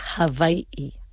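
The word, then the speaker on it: Hawái-I (Hawaiʻi): Y después la palabra incluso Hawái, esa W en hawaiano, ¿cómo se dice? Se suena como una V. Y las dos I, ninguna vocales iguales van seguidas. Siempre está separadas por un okina, un signo diacrítico. Entonces, realmente, como se pronuncia correctamente, Hawái es Hawái-I.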